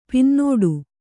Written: ಪೊನ್ನುಡೆ

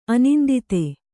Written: ಅನಿಂದಿತ

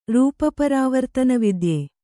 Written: ರೂಪಪರಾವರ್ತನವಿದ್ಯೆ

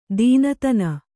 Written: ದೀನತನ

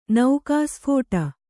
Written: ನೌಕಾಸ್ಫೋಟ

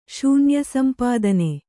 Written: ಶೂನ್ಯಸಂಪಾದನೆ

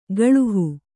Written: ಗಳುಹು

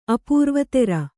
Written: ಅಪರಾವತಾರ

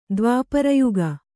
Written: ದ್ವಾಪರಯುಗ

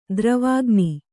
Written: ದ್ರವಾಗ್ನಿ

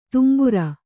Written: ತುಂಬುರ

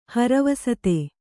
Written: ಹರವಸತೆ

